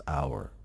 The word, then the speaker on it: hour: hour حرف h نیست در اولش, تلفظ اولش با حرف صدادار هست, hour.